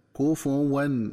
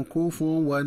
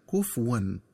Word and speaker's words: كفوا: وكذلك ينبغي التنبه للنطق الصحيح لكلمة كفوا، فهي تقرأ بضم الفاء هكذا: كفوا ولم يكن له كفوا أحد. – ومن الخطأ قراءتها بتسكين الفاء هكذا: كفوا ولم يكن له كفوا أحد.